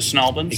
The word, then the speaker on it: St. Albans: Do you pronounce it Snalbans? – Exactly the same, because there are a lot of things we don't pronounce the same. And that is one that we do.